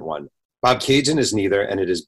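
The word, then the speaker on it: Bobcaygeon: I did pronounce that correctly, right? – Bob Cajun. That's perfect. – Bob Cajun. I just want to say the spelling of Bob Cajun is B-O-B-C-A-Y-G-E-O-N, a town in Ontario.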